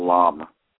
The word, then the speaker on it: lam: It's actually spelled L-A-M, but it's pronounced lam.